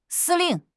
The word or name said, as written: 司令